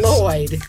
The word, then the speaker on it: Lloyd: Put your tongue where you're going to about to say sugar and then instead blow out on the sides. – Say Lloyd.